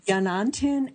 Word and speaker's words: Yanantin: And the book we're going to discuss today, Hilary, you will correct me if I mispronounce it, Yanantin and Masintin in the Andean World, Complementary Dualism in Modern Peru.